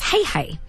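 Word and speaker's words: Heihei: But they've actually named him, I believe it's pronounced Hey Hey, after the bird in the film Moana, who stows away on her boat.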